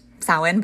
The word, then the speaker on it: Samhain: Samhain, which, you know, is pronounced Samhain, but is spelled like Samhain or S-A-M-H-A-I-N, but it is actually a Gaelic word.